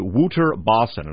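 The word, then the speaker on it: Wouter Basson: that is a fellow named Wouter Bossen, and I may be mispronouncing that. It's an Afrikaans name, W-O-U-T-E-R, and the last name is B-A-S-S-O-N.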